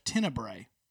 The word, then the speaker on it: Tenebrae: inspired by the ancient Christian tradition of Tenebrae. Did I say that right? You did.